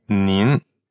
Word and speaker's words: nin: So the difference here is ni, just the simple you in pinyin is spelled N-I. – And it's third tone. – Right. And nin is N-I-N. – And that's second tone.